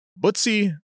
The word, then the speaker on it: Butzi: Butzi, it's spelled B-U-T-Z-I. – Butzi.